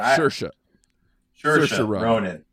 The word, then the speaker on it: Saoirse Ronan: And Cerise Ronan. I always butcher her name. But Saoirse Ronan.